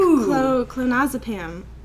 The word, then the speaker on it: clonazepam: And now I take clonazepam. That's how it's pronounced.